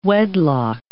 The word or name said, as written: wedlock